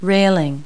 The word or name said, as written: railing